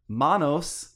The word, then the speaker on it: Manos: Uh, so the movie is Manos, the hands of fate. – Mm. and I'm saying it with the uh, correct Spanish pronunciation of Manos, but I think that at times in the film they go back and forth, Yeah.